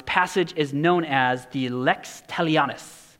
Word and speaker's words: Lex Talionis: So, Lex Talionis.